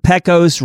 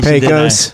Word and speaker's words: Pecos: 8367 East Pecos Road. By the way, they're not. I just blew the pronunciation. – Pecos. – Didn't I? Pecos. – Pecos.